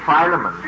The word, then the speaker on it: Philemon: I knew you would have two or three pronunciations of it. But it's Philemon. Marjorie, you looked it up. Philemon, that's the third, you see.